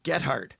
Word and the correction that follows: Goedhart: Goedhart. Annelies Goedhart.